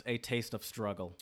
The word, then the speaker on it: A Taste of Struggle: It's by, if phonetically it's spelled A/T/O/S. Mm-hmm. However, I did some digging, and it's pronounced A Taste of Struggle. That's how you would say it.